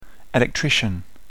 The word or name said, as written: electrician